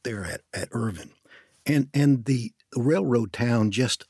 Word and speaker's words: Irvine: Well, let's start with that very famous place on the Kentucky River where Irvine, or Irvin as they pronounce it, now sits.